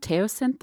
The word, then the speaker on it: teosinte: Teosinte, the original corn? – Yeah.